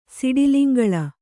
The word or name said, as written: ಸಿಡಿಲಿಂಗಳ